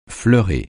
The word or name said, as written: fleurer